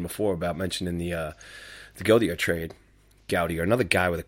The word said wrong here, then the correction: Gauthier: How do you say his name, KD? – I think it's Gauthier. – Gauthier. All right. Yeah, that sounds about right.